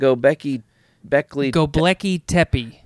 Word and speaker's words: Göbekli Tepe: Göbekli Tepe. – Göbekli Tepe.